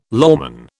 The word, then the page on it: lawman: British English